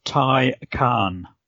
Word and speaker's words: Taycan: How do I pronounce something that looks like Taycan? – You and the rest of Australia are going to have a real problem getting their mouths around the pronunciation. It is the Porsche Taycan.